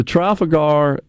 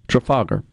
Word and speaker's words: Trafalgar: Well, I will say that the Trafalgar and the uh, Trafalgar, pardon me, is the way it's pronounced,